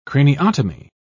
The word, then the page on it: craniotomy: British English